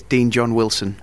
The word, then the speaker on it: Dean John Wilson: Yes, Dean John Wilson.